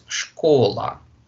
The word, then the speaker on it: школа: So here it sounds школа.